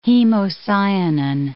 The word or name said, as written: hemocyanin